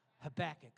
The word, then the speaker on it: Habakkuk: Do you like, is that how you say it, Habakkuk, or do you say Habakkuk? Habakkuk. Okay.